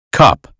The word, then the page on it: cup: American English